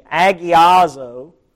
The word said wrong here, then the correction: hagiazo: I should pronounce this one hagiazo because of the little strong breathing mark on top of the first letter. Hagiazo. Hagios, to be made holy.